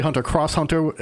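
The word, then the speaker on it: Hunter × Hunter: You don't pronounce if it's a lowercase X, it's pronounced Hunter Hunter. – It's pronounced Hunter Hunter. – Then we don't pronounce the X here either, and therefore John's. – It's also, or you could say Hunter Cross Hunter.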